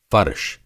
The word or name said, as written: फ़र्श